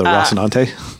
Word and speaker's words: Rocinante: Is this about the Rocinante? – Is that how you say it? – That's how they say it on the show.